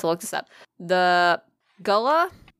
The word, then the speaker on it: Gullah: Yeah, Gullah. That's correct. – Gullah.